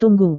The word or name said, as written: tunggu